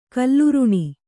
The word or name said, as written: ಕಲ್ಲುರುಣಿ